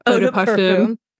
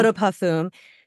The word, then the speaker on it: Eau de Parfum: How do you say? – Eau de Parfum. – Yeah. Eau de Parfum.